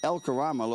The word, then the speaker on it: El Karama: El Karama Lodge is a private wildlife Conservancy.